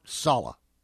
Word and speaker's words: Saleh: It's like Hala with an S. Robert Saleh.